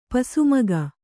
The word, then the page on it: ಪಸುಮಗ: Kannada